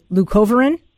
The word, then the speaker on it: Leucovorin: Leucovorin? – Yes.